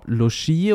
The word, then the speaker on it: Lucci: Äh, wird der italienisch ausgesprochen, also Rob Lucci oder wird er, äh, wie, wie der Deutsche Luki.